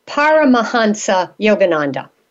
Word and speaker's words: Paramahansa Yogananda: So welcome back, and tell us about, I'm going to say it, I hope I say it right, Paramahansa Yogananda. All right, go ahead. – You did a good job. – Thank you. – I know, okay. Paramahansa Yogananda